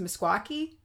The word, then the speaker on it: Meskwaki: And the pronunciation that I read for this is pronounced Meskwaki.